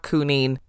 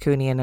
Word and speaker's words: Coonian: Which I discovered post-podcast and then was like, oh god damn it, it's Coonian, not Coonine. So if anyone out there from Fermanagh or who knows the story and knows that it's pronounced Coonian and not Coonine is listening, this is my formal apology because I got it wrong.